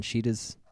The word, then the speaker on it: Shida: S-H-I-D-A? – Yeah, Shida. Because in that culture, they do pronounce the R's as D's. So, Hakura. Like, you say the R, but you let your tongue touch the two.